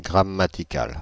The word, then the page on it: grammatical: /ɡʁa.ma.ti.kal/; [ɡʁa.ma.ti.kal]